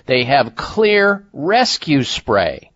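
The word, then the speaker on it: Xlear: And by the way, Clear is spelled with an X, X-L-E-A-R,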